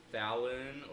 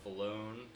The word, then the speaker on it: Falon: Fallon or Falone, depending on how you want to pronounce F A L O N.